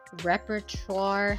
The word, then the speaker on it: repertoire: Apparently by the time Harriet arrived, she had already had conversations with everybody there, like kind of created like a repertoire. How do you say that? – Repertoire. Yeah, you were right.